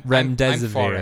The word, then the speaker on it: Remdesivir: Your headline story put some uh, put some light on the Ramdab Ramdamavizir. – I'm Remdesivir.